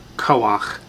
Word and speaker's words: koach: When you have a chait with a patach underneath, it's pronounced ach, not cha. So, koach, like noach for Noah.